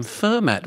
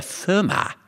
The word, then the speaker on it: Fermat: Paul and the other panellists have been calling him Fermat, where it could be argued Pierre Fermat is the correct pronunciation.